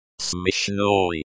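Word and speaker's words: смешной: Pronunciation guide. Smishnoi. Smishnoi, smi as in smell, sh as in ship, noi as in noise.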